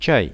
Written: chai

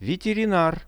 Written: veterinar

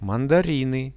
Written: mandariny

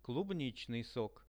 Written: klubnichnyi sok

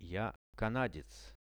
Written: ja canadec

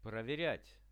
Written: proveriat

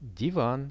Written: divan

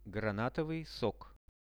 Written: granatovyi sok

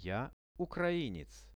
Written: ja ukrainec

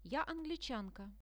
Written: ja anglichanka

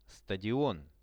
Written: stadion

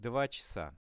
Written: 2-chasa

